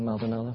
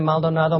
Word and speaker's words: Maldonado: You did very good, by the way, pronouncing Maldonado. Congratulations. It sounds kind of like McDonald's. But we've been in Maldonado.